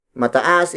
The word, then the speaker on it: mataas: Taas is spelled as T-A-A-S. When used as an adjective, it becomes mataas. Mataas. Mataas. Mataas is spelled as M-A-T-A-A-S. It means high or tall. Remember that adding the M-A prefix to a root word turns it into an adjective. Mataas is used to describe tall or high objects.